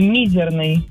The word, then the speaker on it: мизерный: Ну, когда не задумываешься, то да, но всегда делала ударение на букву «и», мизерный.